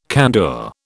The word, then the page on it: candour: British English